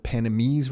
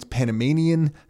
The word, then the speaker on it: Panamanian: You hear me reference the word Panamese residency, and that's incorrect. I think it's Panamanian. I think that is the proper way to say it. So when you hear me say that, that is completely wrong.